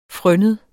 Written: frønnet

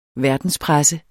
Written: verdenspresse

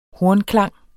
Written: hornklang

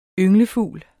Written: ynglefugl

